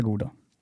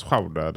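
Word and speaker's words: Gouda: Det visste du från början att det heter goda. och inte Schauder. – Nej, det är sant. Men att det uttalas Schauder där det kommer ifrån.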